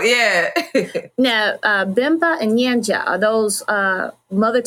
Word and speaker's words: Bemba: So he's speaking in, is it pronounced Bemba? – So Chef is rapping in Bemba, and Tio is singing the hook in Nyanja.